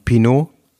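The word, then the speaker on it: Pinault: wie wird der ausgesprochen? Pinot? Pinot, ja. Pino, ne? Wie Macron.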